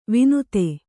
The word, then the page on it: ವಿನುತೆ: vinute